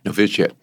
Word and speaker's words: novitiate: The diaries started with the novitiate, how do you say that word? – Novitiate.